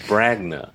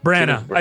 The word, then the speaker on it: Branagh: I thought it was Bragna.